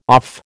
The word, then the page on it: off: American English